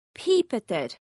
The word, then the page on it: Pìobadair: Scotland